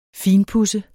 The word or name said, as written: finpuds